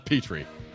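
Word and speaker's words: Petri: Petrie.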